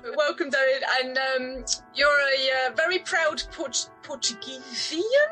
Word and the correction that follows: Portuguese: Portuguese? How do you say that? – Portuguese. – Portuguese.